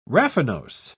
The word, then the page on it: raffinose: British English